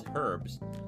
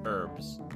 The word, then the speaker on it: herb: The British pronunciation is herbs with an H, while American pronunciation is herbs without the H.